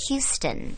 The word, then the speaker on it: Houston: and go to Canal and it was like a year later that someone kept saying Houston, Houston. I was like, no, it's pronounced Houston. That's where my parents live.